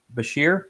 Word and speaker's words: Beshear: Kentucky's Governor Andy Beshear, is that how you pronounce his name? – Uh-huh.